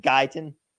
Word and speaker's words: Guyton: Is it phonetic? Is it just Guyton? Like G-U-Y-T-O-N? – Absolutely.